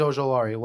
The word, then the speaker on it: Ojulari: Azeez Ojulari, look up what happened to him at the draft, please.